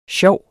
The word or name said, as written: sjov